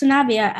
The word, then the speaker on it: Sanabia: We have former Marlins pitcher Alex Sanabia.